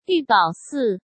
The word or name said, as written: Yu Baosi